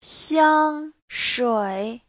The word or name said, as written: xiang1shui3